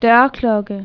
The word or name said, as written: dzwonek u drzwi